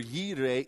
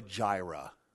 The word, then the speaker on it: Yireh: Now, if you've been around the church for any length of time, you've probably heard the Greek pronunciation, and that is Jehovah Jireh. Jehovah Jireh, our provider. It's an absolute butcher of the Hebrew, so stop saying it if you say that.